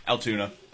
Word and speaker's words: Altoona: It's going to be fun at the Altoona, or is it Altona? I don't know. Which is it? – Altoona.